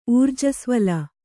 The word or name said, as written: ಊರ್ಜಸ್ವಲ